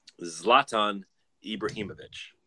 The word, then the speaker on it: Zlatan Ibrahimovic: Zlatan Ibrahimovic. – That's very good. Is that correct pronunciation, or is that just... – That's his name. Yeah. – That's pretty good. – Slohan? – Nope.